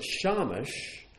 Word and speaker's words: Shamash: And that word could be pronounced two different ways. You pronounce it Shamash, it means servant.